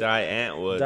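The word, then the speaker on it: Die Antwoord: We either talk about weird sex with Di Artwood. Is that how you say it? – Di Antwood. – Di Antwood. That's what it was.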